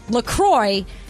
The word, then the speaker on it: La Croix: And they even say on their website, it's definitely pronounced La Croix. And if you ever get confused about it, just remember it rhymes with enjoy.